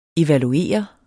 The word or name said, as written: evaluere